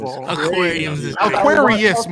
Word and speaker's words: Aquarius: If I pick Damo, who is an Aquarium, he can only draft Aquarium. – An Aquarium. – Aquarius, motherfucker. Hey, say it right.